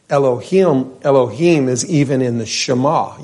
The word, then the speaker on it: Elohim: The correct pronunciation is actually, I believe, Elohim, but that is not a new word in church, right? Elohim or Elohim.